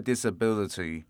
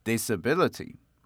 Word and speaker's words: disability: So the first tip we have here is how to pronounce the word disability. So disability is a combination of dis and ability. So the same as ability, the stress should be on the sound B. So ability, then disability. Disability.